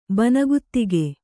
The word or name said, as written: ಬನಗುತ್ತಿಗೆ